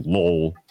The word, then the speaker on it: Lowell: No. Lowell. Like Lowell, like with the L-U-L-L.